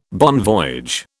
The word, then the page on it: bon voyage: British English